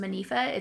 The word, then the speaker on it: Monifah: I believe her first name is pronounced Monifah, it's M O N I F A H, Shelton is her last name.